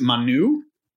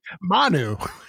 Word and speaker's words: Manu: Manu or Manu? – Manu. You've got a chance of putting the emphasis on the right syllable, and you fucking miss it every time.